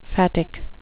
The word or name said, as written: phatic